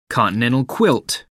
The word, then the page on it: continental quilt: American English